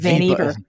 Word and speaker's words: Vannevar: sets a model for the form of research that would come after war due to, I've heard Vannevar and Vannevar. Let's just call them Vannevar. – Vannevar. – Rhymes with Beaver.